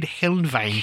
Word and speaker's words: Helnwein: He was heavily influenced by Gottfried Heinlein. Helnwein. I can't ever pronounce that. Gottfried Helnwein.